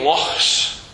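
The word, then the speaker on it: loch: We've only got one lake, but we have lochs. Okay, lochs. That's how you pronounce it.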